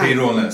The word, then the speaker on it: Peirone: Peirone, säger jag rätt? – Du säger det. – Det är ett väldigt svårt namn om man bara ska uttala sådär. Vilket ursprung kommer det? – Jag är från Argentina, född i Argentina. Egentligen är det inte så svårt, men den är ju ovanlig så folk tänker alltid att det ska uttalas på något konstigt sätt. Men det uttalas egentligen bara som det står.